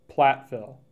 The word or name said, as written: Plattville